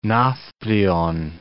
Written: Navplion

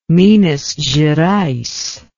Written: minas gerais

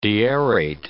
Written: deaerate

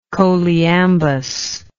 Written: choliambus

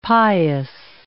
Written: pious

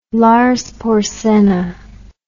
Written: Lars Porsenna

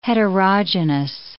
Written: heterogenous

